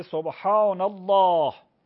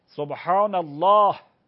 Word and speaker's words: سبحان الله: سبحان الله، منتها همزش خونده نمیشه، همزش همزه وصله، خونده نمیشه، میشه سبحان الله. لا نداره که، سبحان الله. خب ه داره آخرش، ه باید تلفظ شود. حالا یا وقفش میکنی سبحان الله، حرکت بهش نمیدید، یا حرکت میدید، حرکتش هم چیه؟ کسره است.